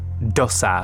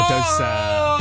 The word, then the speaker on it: dosa: Okay, it rhymes with... Samosa. – Samosa. I don't know. What is it? – It's dosa or dosa.